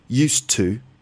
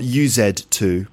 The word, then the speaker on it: used to: that's because used to, U S E D T O, used to, note the pronunciation there, used to. Not UZ to, but used to.